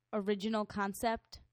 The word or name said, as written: original concept